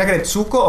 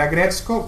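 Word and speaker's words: Aggretsuko: Eh, puedes decir Agretsuko o puedes decir Agretsuko, que es como.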